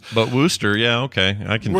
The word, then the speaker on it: Worcester: You've mispronounced it wrong a few times. It's Worcester. Very simply, Worcester.